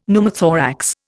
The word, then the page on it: Pneumothorax: Indian